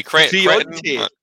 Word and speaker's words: Creonte: I think you're a Creonche then. Creonite. I never know how to say it properly. – Creonte. Yes.